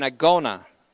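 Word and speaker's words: agona (ἀγῶνα): In previous studies, and in this study on Hebrews, we've identified our own time as an agona, A-G-O, long O, that is, N-A,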